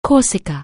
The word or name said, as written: Corsica